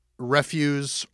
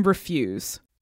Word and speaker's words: Refuse: So you're editing a book that I think alternately can be pronounced Refuse or Refuse? – Uh-huh. – Is there a preferred? – I think we're mostly saying Refuse.